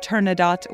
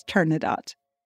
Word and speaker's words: Turandot: And if you're more familiar with the opera and you're wondering why I'm pronouncing Turandot with a T, it's because the word is Persian and Puccini, like me, wasn't great at pronunciation. But in this case, the Persian word is Turandot.